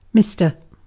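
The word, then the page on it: herra: /ˈhe.rːɑ/; Finnish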